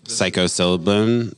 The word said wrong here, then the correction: psilocybin: What is it called? Did I say that right? psilocybin, psilocybin, mm-hmm.